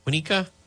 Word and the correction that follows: Waukesha: I already forget how to pronounce that. Where the Christmas parade was. Waukesha. There we go.